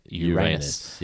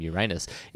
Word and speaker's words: Uranus: Uranus, is that it is not actually pronounced Uranus.